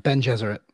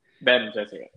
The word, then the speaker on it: Bene Gesserit: The voice training for the Bene Gesserit. I think I said that right. – Ben Gesserit. – Ben Gesserit. Yeah.